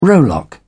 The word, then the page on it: rowlock: British English